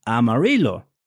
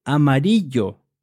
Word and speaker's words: amarillo: And as I'm talking about colors, there is a place in Texas that people know as Amarillo. That means yellow. However, the double L in Spanish is pronounced yo. That's why we would say amarillo and not amarillo.